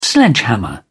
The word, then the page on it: sledgehammer: British English